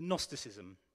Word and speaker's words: Gnostics: You know, and the church went on to really promote this in the Gnostics. Starts with a G, it always throws me, but you pronounce it with an N. The Gnosticism really took hold of this.